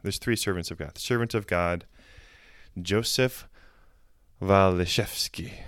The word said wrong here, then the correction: Walijewski: Walijewski, sorry. – Mm. Or Walijewski, Walijewski. I'm not sure how they say it in Wisconsin, but mm.